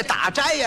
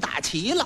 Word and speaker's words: 打斋: 他也错了一个，这打斋呀、他给念成打齐了，斋字他念成齐了。